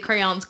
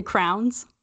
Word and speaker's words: crayons: And The crayons. Yes, obviously that is the correct way to say it. But some people say crowns. I did when I was a kid. And some people say crane or crayons. Like cranberries. – Crayons. Yeah. – I hate it. – Yeah, no. It has two syllables.